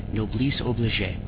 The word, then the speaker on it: Noblesse Oblige: I can never say their clan name right. I've been told 4 times. This is how you say it. Noblesse Oblige.